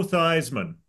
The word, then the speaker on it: Theismann: Joe Theismann, who changed the pronunciation of his last name to rhyme with Heisman.